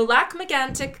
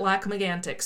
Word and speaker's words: Lac Megantic: Now I'm pretty sure the French pronunciation is like Lac Megantic. – Depends on where that Canadian is from. – Right. Anglo-Canadians, from what I can tell, Erica pronounces it Lac Megantic, so I feel good about calling it that.